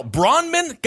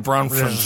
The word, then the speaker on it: Bronfman: It's literally Bronfman. And I'll tell you what it is. It's that F. They stick right in the middle. Bronman? Got it. Bronfman.